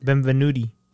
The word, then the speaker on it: Benvenuti: And then the writers were Leo Benvenuti. – Benvenuti. – Did I say that right? – Benvenuti. – Benvenuti. – Yeah.